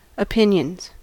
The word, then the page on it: opinions: American English